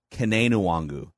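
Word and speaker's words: Kene Nwangwu: I went back to the Iowa State play-by-play guy, multiple games. Kane Nwangu is how they pronounced it in Iowa State, and that is the college that he played at for 4 years.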